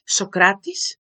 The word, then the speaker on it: Socrates: It's like Socrates, Platonas, Aristoteles. So this is the correct pronunciation for all three of them, but well done, spot on about Socrates.